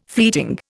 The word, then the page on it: fleeting: Indian